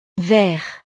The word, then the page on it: vert: frances